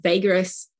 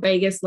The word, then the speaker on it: vagus: And the vagus nerve, I think the word vagus, and it's spelt V-A-G-U-S, not Vegas like Vegas, Las Vegas.